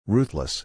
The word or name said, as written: ruthless